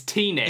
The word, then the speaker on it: Tenet: It's not Tenet. That would be T-E-N-N-E-T. Which would have been fine, but it's just... – It's T-net. – It's just... It's Tenet. I agree, you don't pronounce you don't pronounce it like David Tennant. It's Tenet. – Tenet. – There's no second N.